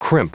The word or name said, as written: crimp